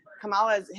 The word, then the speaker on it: Kamala: and I can't pronounce her name right out. You guys are saying it so perfectly. I'm like, Kamala, I can't even say it right, but you guys all have it. Kamala. – No, you said Kamala.